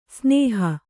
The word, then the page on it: ಸ್ನೇಹ: snēha